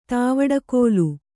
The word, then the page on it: ತಾವಡಕೋಲು: tāvaḍa kōlu